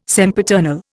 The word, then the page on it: sempiternal: Indian